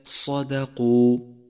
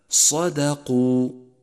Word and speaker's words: صدقوا: ثم تأمل كيف تتالى الاستعلاء والاستفال في كلمة صدقوا، فالصاد حرف استعلاء والدال حرف استفال، وهذا يعني انتقال النطق بهذين الحرفين من التفخيم إلى الترقيق، أي هكذا صدقوا، ومن الخطأ أن تقول صدقوا، فلا يميز المستمع بين تفخيم الصاد وبين ترقيق الدال.